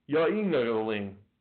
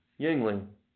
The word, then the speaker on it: Yuengling: Number three, Yuengling. That's how it's pronounced. Yuengling.